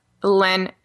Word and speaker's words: LEN: Yeah, that's len, L-E-N.